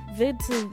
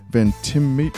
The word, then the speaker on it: Ventimiglia: Milo Vitu. Mm-hmm. I can't pronounce people's names. – It's Milo Ventimig. – Mm-hmm. – Oh, wow. – Yeah. – Ventimiglia. Ventimiglia? – Okay.